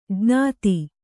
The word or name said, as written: ಜ್ಞಾತ